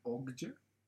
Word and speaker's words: Okja: Bueno, creo que se pronuncia Okja.